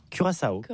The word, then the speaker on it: Curaçao: Donc comment on prononce Clara? – Curaçao. – Ah oui, Curaçao, j'aime bien.